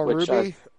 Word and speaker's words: RWBY: Oh, Ruby? – Is that how it's pronounced? – And that's how I've been told it's pronounced, yeah.